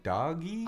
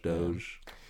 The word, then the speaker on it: Dogecoin: I was having trouble pronouncing that. I was like, doggy? – I've heard Dodge, too. – Yeah, it's Dodge, right? – Dodge, Doge.